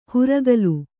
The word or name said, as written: ಹುರಕಲು